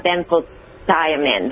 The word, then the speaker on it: benfotiamine: Benfotiamine, that's how you say it.